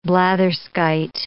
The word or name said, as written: blatherskite